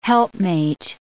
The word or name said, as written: helpmate